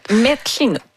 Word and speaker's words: Metlinut: Je ne sais pas si je prononce bien, mais c'est quelque chose comme Messinut. – Metlinut. – Ah, merci.